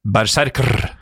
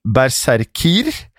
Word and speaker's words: berserkr: Berserker ble skrevet berserker med rkr, RKR, altså, på slutten uh, som visstnok ble uttalt berserkr. I flertall berserkir.